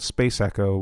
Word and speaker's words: SPC ECO: The band is called Space Echo with it's spelled SPC space ECO.